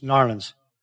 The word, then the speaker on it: New Orleans: It's not New Orleans, it's Narlands. It's Narlands.